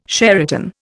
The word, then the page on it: Sheraton: Indian